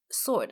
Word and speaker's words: sword: 然后 sword 大家要记得就是 sword，它虽然拼法是 s-w-o-r-d。它的那个 W 其实是没有发音的，它是剑的意思。